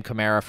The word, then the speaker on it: Kamara: I'm pronouncing his name Kamara forever going forward. Historically, I've gone back and forth between Kamara and Kamara. And I'm sticking with Kamara.